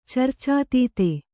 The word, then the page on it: ಚರ್ಚಾತೀತೆ: Kannada